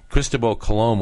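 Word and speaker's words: Cristóbal Colón: I'll use the correct pronunciation as they use in España. Cristobal Colón, when he founded the New World, it was claimed for the Pope and the Spanish king and queen.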